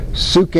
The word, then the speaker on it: psyche: Suke in Greek, but it's our English word psyche. That's how we pronounce it in English, suke.